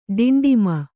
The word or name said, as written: ಡಿಂಡಿಮ